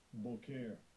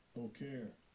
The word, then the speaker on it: boker: Boker. Boker.